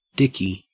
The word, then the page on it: dicky: Australian English